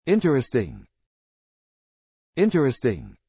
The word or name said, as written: interesting